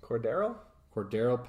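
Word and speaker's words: Cordarrelle: Is it Cordell or Cordarrell? – Cordarrell Patterson.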